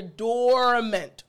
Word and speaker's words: dormant: Now, this is a short word, and it's pretty simple to pronounce, but I want to emphasize or place emphasis on the first part of this word, the or portion. At the beginning of the word, you hear dormant, right?